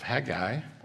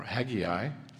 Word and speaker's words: Haggai: Let's open God's Word together, and we'll be looking in the book of Haggai, or Haggai. Depends how you like to pronounce it.